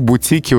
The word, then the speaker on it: бутике: А еще в бутике ударение остается на корне и даже в, при изменении слова. В бутике вот неправильно было бы, в бутике. Но в кожухе. Вот здесь ударение переходит на окончание.